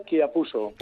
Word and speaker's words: Chiapuso: Bueno, En, realidad es italiano y se pronunciaría Chiapuso.